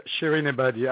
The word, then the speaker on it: Shirin Ebadi: I'm not sure I'm pronouncing her name right. Shirin Ebadi, is that right? – Yeah, Shirin Ebadi, absolutely.